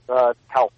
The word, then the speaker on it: Towson: Yes, Towson.